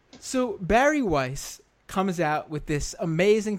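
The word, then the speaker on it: Bari Weiss: This piece by, I think you pronounce her name Barry Weiss, right? – Number one sweetie, Barry Weiss.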